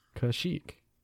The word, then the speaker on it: Kashyyyk: Kashyyyk.